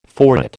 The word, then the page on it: forehead: American English